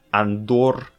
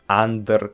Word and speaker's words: Андор: Вот, кстати, по-разному делают ударение. Андор, Андер. Короче, я говорю Андор.